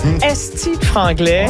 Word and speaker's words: Esti franglais: Esti franglais. Ouais. Est-ce que je le dis bien? – Oui, très bien.